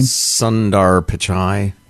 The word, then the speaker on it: Sundar Pichai: Sundar Pichai.